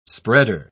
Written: spreader

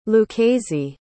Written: lucchese

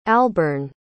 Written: albern

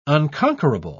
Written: unconquerable

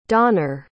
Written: donar

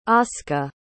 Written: ausca

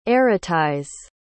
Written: erotize

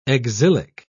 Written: exilic

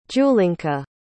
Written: julinka

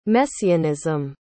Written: messianism

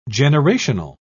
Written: generational

